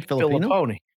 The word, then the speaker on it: Fillipponi: Andrew, and how do you say his last name? – Fillipponi. – Fillipponi.